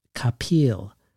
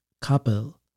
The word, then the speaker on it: Kapil: Speaking of failure, I've noticed over the years, and I've been thinking about this conversation for years now, that almost everyone pronounces Bhanu's last name Kapil, like an orange peel. And yet the rare times I've heard her say it, most notably in a performance in the voice of her father, it sounded more like Kapil to me.